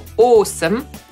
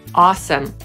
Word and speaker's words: awesome: Podsumowując, angielskie słowo, które czasami po polsku wymawiamy sobie jako awesome, zapisujemy awesome, oznacza niesamowity i wymawiamy je po brytyjsku jako awesome, po amerykańsku awesome.